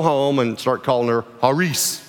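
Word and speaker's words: haris (χάρις): Our English pronunciation of it is charis. And put the word up so you can see how it's spelled. And it's fine to say that, but that's really not how it's pronounced. The C in Greek is silent. And so this word is actually pronounced haris. Haris. The I would be like a long E.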